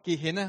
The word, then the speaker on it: Gehenna: Det står deroppe til øverst, Gehenna. Det er sådan, vil det blive udtalt.